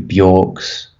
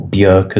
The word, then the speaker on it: Björk: You know, your Childish Gambinos, your Kanyes, your David Bowies, your Bjorks, or Björk as we're meant to pronounce it.